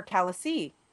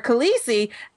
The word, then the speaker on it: Khaleesi: They're calling her Khaleesi. What is wrong with my pronunciation tonight? They're calling her Khaleesi, and treating her like royalty and not really giving him any respect.